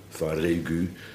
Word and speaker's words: Varegu: Varegu. Varegu. Want dan wordt de B uitgesproken als een V.